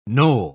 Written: knoll

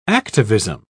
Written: activism

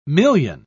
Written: million

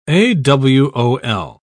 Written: awol

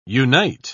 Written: unite

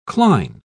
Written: cline

